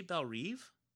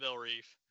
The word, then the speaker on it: Belle Reve: I definitely said Belle Reve. – Fair enough. But he says the Reve should have a short E and not a long.